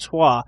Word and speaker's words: trois: Well, the French in there, the three is supposedly supposed to be pronounced trois, as in partial trois.